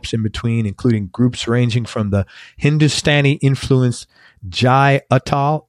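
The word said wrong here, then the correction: Jai Uttal: Do I pronounce that right? – Utah. – What is it? – Utah. – Jai Utah.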